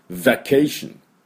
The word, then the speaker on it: vacation: バケーションはですね、 V ですから、ズ、ズ、ズ、ズ。この音です。 F。昨日もやりましたね。 Survey でやったのと同じで、ズ、ズ。 Vacation。 Vacation。で、この VK のところにアクセントがありますね。 Vacation です。